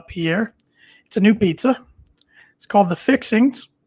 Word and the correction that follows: Fixins: The Fixins. I didn't say it right. The Fixins. And it is consisted of mashed potatoes, turkey, ham, corn, chives, and cheddar cheese.